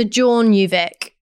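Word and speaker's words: Tjørnuvík: So, Tjornjuvik. – Great. – I'd say. – That sounded better.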